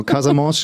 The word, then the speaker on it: Casamance: Genau, Casamance schreibt man auch mit C, aber man spricht mit K.